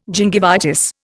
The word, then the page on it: gingivitis: Indian